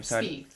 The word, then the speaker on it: Spieth: How do you say your last name? – Spieth. – Spieth? Like okay. – Teeth. – Okay. Like teeth. Okay, I have been saying it right then.